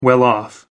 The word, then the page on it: well-off: American English